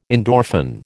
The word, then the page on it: endorphin: American English